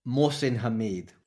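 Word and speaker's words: Mohsin Hamid: Mohsin Hameed.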